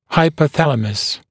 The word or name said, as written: hypothalamus